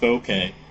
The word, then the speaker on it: bokeh: I think that's pronounced bokeh.